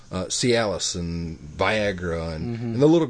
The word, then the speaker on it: Cialis: I mean, and this is stuff that will come to me, and it has a certain message, and it's Callus pills, or how do you say that? Calius? Callus? – I don't know. Cialis.